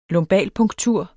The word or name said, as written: lumbalpunktur